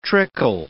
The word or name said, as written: trickle